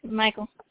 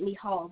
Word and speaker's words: Michal: Michael. – Yeah. I pronounce it Michal, but yeah.